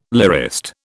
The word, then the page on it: lyrist: British English